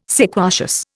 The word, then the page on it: sequacious: Indian